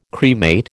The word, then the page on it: cremate: American English